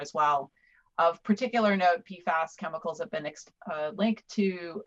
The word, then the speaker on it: PFAS: So that's the pronunciation, PFAS.